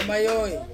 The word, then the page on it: mayoi: Ansus